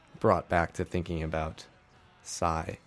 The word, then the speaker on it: HSY: And also before that, I played a band called Psy, is how I pronounce it, H-S-Y, from Toronto, kind of a noise rock, I don't know, it's noisy shoegaze music from Toronto.